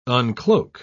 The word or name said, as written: uncloak